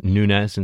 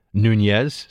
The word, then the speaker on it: Nunes: Many of you have been reaching out to me to tell me it is Nunes instead of Nunez. So I will go with those of you who believe it is only properly pronounced Nunes.